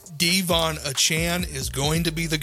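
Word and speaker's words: De'Von Achane: He said, well, now that I've done that, I'm going to ask the announcers to pronounce my name properly. Devon Achane. – Devon Achane. – His name is pronounced Devon Achane.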